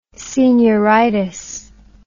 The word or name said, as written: senioritis